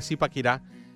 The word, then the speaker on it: Zipaquirá: Precisamente estábamos diciendo eso, te voy a hacer una pequeña corrección, de Zipaquirá, em, como se pronuncia, y eh, le faltó el acento aquí.